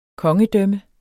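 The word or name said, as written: kongedømme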